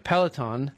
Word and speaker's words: Peloton: Yeah, I said that right. Peloton,